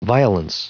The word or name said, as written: violence